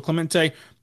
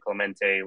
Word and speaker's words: Clemente: So, Clemente, thanks for joining us.